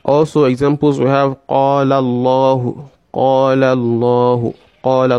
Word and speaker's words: Allahu: For instance, Allahu Nuru Samawati wal Arb. Allahu Nuru Samawati wal Arb. So you say Allahu, Allah, Allah. That is pronouncing the lamun with what? With the mufakham.